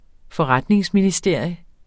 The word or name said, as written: forretningsministerium